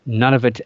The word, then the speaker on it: Nunavut: Nunavut, or it's spelled N-U-N-A-V-U-T. It's very, if you look it up, people argue about what it's, how it's pronounced, but Nunavut, that's how I pronounce it.